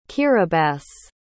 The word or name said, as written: kiribati